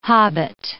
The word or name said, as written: hobbit